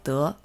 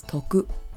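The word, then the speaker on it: de (徳): In Chinese, this is pronounced as de. And in Japanese, this is pronounced as toku.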